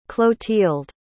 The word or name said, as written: clothilde